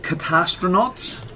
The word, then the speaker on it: Catastronauts: Okay, so it's called Catastronauts.